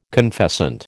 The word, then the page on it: confessant: American English